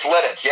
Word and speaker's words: Lititz: I don't really know how to pronounce the name of the town. It's Lititz? – It's Lititz, yep.